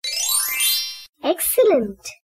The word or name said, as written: Excellent